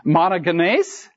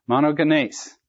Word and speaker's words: Monogenes: Monogenes. M-O-N-O-G-E-N, and it's a long E. Put a line over your E-S. Monogenes.